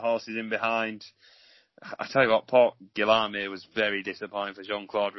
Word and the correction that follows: Guillaume: That's the worst French pronunciation you've ever come out with. – What did they call him, Port Guillaume? – Guillaume.